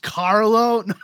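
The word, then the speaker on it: Karlo: So, Basil Carlo. Better. There you go. – It's pronounced Carlo.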